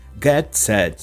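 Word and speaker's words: Gad Saad: Gadsad, que não é a pronúncia original árabe, claro, de seu nome, mas é como ele é chamado nos Estados Unidos ou no Canadá, onde frequenta e vive.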